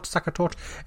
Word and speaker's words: Sachertorte: Saka tort.